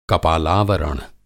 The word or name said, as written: कपालावरण